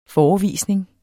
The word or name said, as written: FOREVISNINGERNES